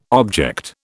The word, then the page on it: object: British English